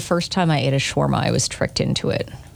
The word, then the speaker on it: Shawarma: Shawarma.